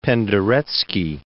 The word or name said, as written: Penderecki